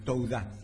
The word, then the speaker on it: Towdah: T-O-W-D-A-H. Touda. – Ah, Touda.